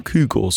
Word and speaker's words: Kygo: Ja, Kygo, so wird Kygo in Norwegen ausgesprochen.